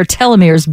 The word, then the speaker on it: telomeres: Telomeres.